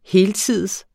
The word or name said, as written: heltids